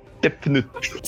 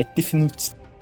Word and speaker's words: Tefnut: Não, mano, a pronúncia egípcia é foda mesmo. Tefnut. – Sendo... Não, é que... É ruim de falar mesmo. É Tefnut. É zoado.